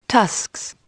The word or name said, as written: tusks